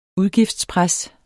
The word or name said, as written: udgiftspres